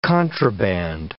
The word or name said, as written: contraband